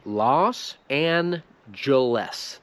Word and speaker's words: Los Angeles: Los Angeles.